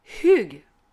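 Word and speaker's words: hygge: donc attention, ils pratiquent le hug.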